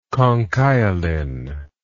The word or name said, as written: conchiolin